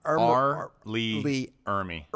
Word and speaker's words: Ermey: R. Lee Ermey. – Ermey, yeah. But E-R-M-E.